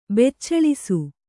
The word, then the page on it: ಬೆಚ್ಚಳಿಸು: beccaḷisu